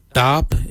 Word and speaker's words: dhaab: Today we will look at, for knife we say darb. Darb is spelled D-H-A-A-B.